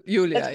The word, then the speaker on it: Yulia: Yeah, I always Julia. – Say Yulia, yeah. – Yulia.